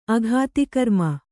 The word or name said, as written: ಅಘಾತಿಕರ್ಮ